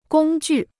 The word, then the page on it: 工具: gōng jù